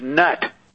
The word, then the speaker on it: Nut: And Carolyn, let's pronounce Nut properly. The mother, Connie, is Nut.